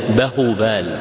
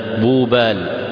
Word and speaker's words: بوبال: وبوبال تكتب في الخط الهندي بإثبات الهاء بعد الباء، إلا أنها لا تلفظ عندهم فلا يقولون بهوبال وإنما يقولون بوبال.